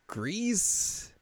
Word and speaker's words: Gris: It's called Gris. It's like the Spanish pronunciation of gray.